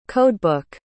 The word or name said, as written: codebook